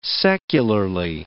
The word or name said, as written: secularly